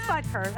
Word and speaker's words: Butker: It is Butker.